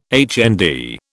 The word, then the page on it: HND: British English